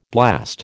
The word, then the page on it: blast: American English